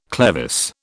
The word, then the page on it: clevis: British English